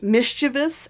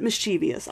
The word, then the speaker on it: mischievous: By the way, if you think that's pronounced mischievous and you think you need to correct me, don't, because I don't care. I pronounce it mischievous. I was actually corrected over on Literotica. Someone posted in the comments of one of my audios, like, I loved the audio. It was so wonderful. One thing, my dear, it's pronounced mischievous, not mischievous. – That all depends on which part of the country you're from, too. – Yeah, and the dictionary says either.